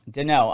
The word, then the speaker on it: Deneau: Yes, it's Deneau. – Deneau. All right, I was right. Yeah, I was right. – Yeah, it's French-Canadian.